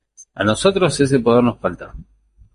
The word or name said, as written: nosotros